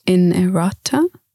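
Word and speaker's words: inerrata: And errata mistakes in a printed work, and it's pronounced innerata. So I think I'm pronouncing it right.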